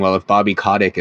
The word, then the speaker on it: Kotick: but Bobby Kotick, is that how you pronounce it? – Yep.